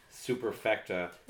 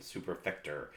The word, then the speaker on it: superfecta: I laughed when you said superfecta, because I'm going to say it wrong. I always put the ER at the end, superfector.